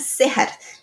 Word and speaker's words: Cejar: O passeio da tarde deste mesmo dia foi Laguna Serrar. Serrar se escreve C-E-J-A-R.